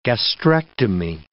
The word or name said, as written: gastrectomy